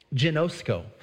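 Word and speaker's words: genosko (γινώσκω): The word know here is pronounced genosko.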